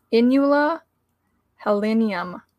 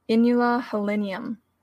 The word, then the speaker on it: Inula helenium: And it's spelled E-L-E-C-A-M-P-A-N-E. So elecampane. Its Latin name, the scientific name, is Inula helenium. Inula helenium. So I-N-U-L-A, Inula helenium, like Helen, H-E-L-E-N-I-U-M.